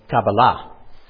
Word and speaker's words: Kabbalah: We put the accent on the first syllable, but in Hebrew you put it on the last or next to the last. So it's Kabbalah.